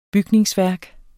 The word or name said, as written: bygningsværk